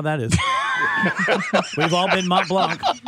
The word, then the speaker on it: Mont Blanc: France's tallest mountain is shrinking, Mount Blanc. Am I saying that right, Kevin? – Mm-hmm. Mm-hmm. Yes. – Yep. – The tallest mountain in France. – Why'd you ask Kevin? – Well, because he's basically It's Mont Blanc.